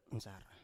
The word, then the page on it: nsar: Biak